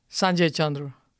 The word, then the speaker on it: Sanjay Chandru: Hey, you just mispronounced my name there. – How do you say it? – Sanjay Chandru. – Sanjay Chandru. – It's okay. You can roll the R's. I'm okay with that.